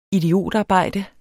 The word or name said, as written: idiotarbejde